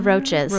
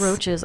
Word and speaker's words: Roches: Is that how you say it? – The Roches. – Roches. Okay, sorry.